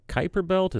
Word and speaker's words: Kuiper Belt: New Horizons is still out there, beyond Pluto, somewhere in the Kuiper Belt. Is that how you say it? – That's right.